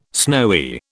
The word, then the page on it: snowy: British English